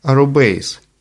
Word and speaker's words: arrobase: Французы же отныне обязаны читать этот символ как «arrobase».